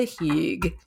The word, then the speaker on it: hygge: The word is H-Y-G-G-E. It is pronounced hygg, and it is the Scandinavian feeling for coziness.